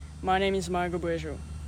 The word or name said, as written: Margaux Brejo